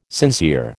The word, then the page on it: sincere: American English